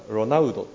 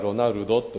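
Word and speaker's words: Ronaldo: サッカー選手で有名なロナウドって言いますよね。ロナウドって今最近では日本語でもウと書きますが、前はロナルドと書いてたんですが、 D の前の L っていうのでウの発音になるので、ロナウドっていうふうに今表記します。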